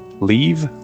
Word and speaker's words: leave: Leave, live, live, life. Aqui as pronúncias parecem um pouco, mas leave, que quer dizer deixar ou ir embora, é mais prolongada do que live, que significa habitar.